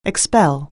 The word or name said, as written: expel